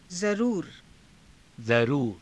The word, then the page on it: zaruura: Hindi